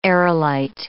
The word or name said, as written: aerolite